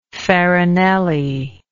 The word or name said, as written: Farinelli